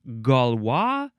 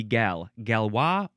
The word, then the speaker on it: Galois: It is Galois theory, or I guess it would be Galois, or Galois, emphasize either syllable. The first word is capital G-A-L-O-I-S.